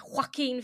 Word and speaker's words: Joaquin: That scene where Joaquin Phoenix, and yes, it is how you say his name because I googled it,